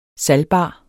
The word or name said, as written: salgbar